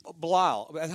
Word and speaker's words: Bilal: and it's kind of funny because – Bilal, how do you say it to me? – So yeah, you said it right, Bilal.